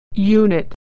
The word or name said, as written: unit